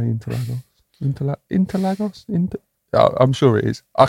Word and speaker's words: Interlagos: It is pronounced something along the lines of Interlagos.